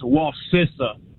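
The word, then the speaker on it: Wacissa: It's Wasissa. I grew up in that area. – Wasissa. Like sister.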